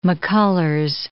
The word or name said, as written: McCullers